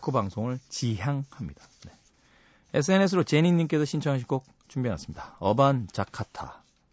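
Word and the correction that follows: 어반자카파: 자카파. 네. 읽을 때마다 틀려요. 어반 자타카, 뭐 이렇게. 어반 자카파.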